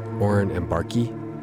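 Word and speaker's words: Oren Ambarchi: And then we start off tonight with a track from Oren and Barkey. I just looked up. I have to do this like maybe every couple months. How do you pronounce Oren? A-M-B-A-R-C-H-I. Oren and Barkey.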